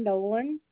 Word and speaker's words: Nolen: Is Sharon Nolan, N-O-L-E-N.